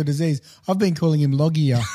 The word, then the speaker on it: Loggia: Is that how you pronounce it? – Loggia. It is. – It is? – Yeah, I've heard it said many times. I'm confident in that.